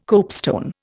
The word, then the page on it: copestone: Indian